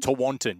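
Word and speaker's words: Tewantin: Tawantin, yes.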